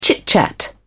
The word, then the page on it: chit-chat: British English